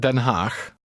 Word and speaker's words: Den Haag: Here's how to say The Hague. – Luister en herhaal. – Den Haag. Haag. Den. Den Haag.